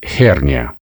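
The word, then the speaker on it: hernia: Это название грыжи, которая в научной латыни пишется и произносится «херния».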